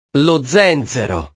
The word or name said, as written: lo zenzero